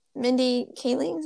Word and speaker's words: Kaling: So this show was directed by Mindy Kaling.